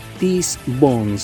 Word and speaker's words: Teese Von: TESSE.BON. Tis Bone se pronuncia.